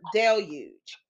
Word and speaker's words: deluge: And to make sure I'm pronouncing it right. Oh, deluge. That's the word, deluge.